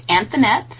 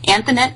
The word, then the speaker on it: Anthonette: It's Anthonette, so the feminine of Anthony, Anthonette Klinkerman.